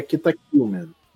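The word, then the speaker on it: Kita Q Man: O nome dele é Kita Q, letra Q, Man, mas se pronuncia Kita Killman.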